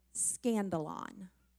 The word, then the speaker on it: skandalon: the word offended comes from the Greek word skandalon. That's a funny sounding word, but it's spelled like this if you're taking notes, S-K-A-N-D-A-L-O-N, skandalon.